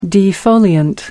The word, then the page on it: defoliant: American English